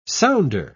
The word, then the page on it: sounder: British English